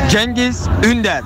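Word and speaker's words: Cengiz Ünder: It's the Genghis Ünder.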